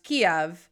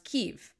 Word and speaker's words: Kyiv: Well, first and foremost, if you've been watching the news, you know that the capital city of Ukraine is not pronounced Kiev, it's pronounced Kyiv.